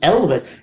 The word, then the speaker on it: Elwes: Elwes. Yeah, I kind of want to say it like Elves.